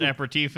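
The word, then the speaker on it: apéritif: It is pronounced aperitif in French and aperitivo in Italy.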